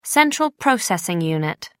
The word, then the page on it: central processing unit: American English